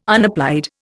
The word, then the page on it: unapplied: Indian